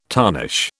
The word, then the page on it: tarnish: British English